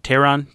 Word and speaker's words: Teheran: I think it's kind of amazing that Julio Teheran, am I saying that right? – Teheran.